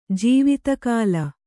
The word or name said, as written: ಜೀವಿತಕಾಲ